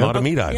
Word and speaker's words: Mahtomedi: Mahtomedi. – Yeah. – I can even spell it. – M-A-H-T-O-M-E-D-I.